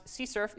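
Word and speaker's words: CSRF: Pronounce CSERF, even though there's no U.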